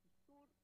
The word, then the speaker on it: Yzur: Está toda la información disponible en la página de la editorial ISUR, que se escribe YZUR, uh-huh. en cualquier buscador que pongan editorial ISUR.